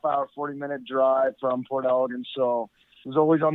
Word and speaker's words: Port Elgin: It's actually pronounced Port Elgin.